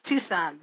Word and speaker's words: Tucson: Is it Tucson or Tucson? Okay, yes, Tucson, but I don't know why it's spelled Tucson.